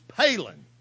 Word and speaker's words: palin: I looked it up, and the word in your Strong's is Palin, like Sarah Palin. That's the way it's spelled, P-A-L-I-N.